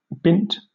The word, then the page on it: bint: British English